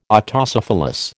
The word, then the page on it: Autocephalous: American English